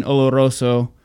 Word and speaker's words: Oloroso: They age them first in ex bourbon barrels, and then they finish it in Oloroso sherry casks.